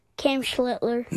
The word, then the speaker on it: Cam Schlittler: Cam Schlittler.